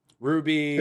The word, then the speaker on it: RWBY: Ruby. – It's spelled R-W-B-Y. It's a series that Rooster Teeth put out. – Oh, Red, White, Blue, Yellow.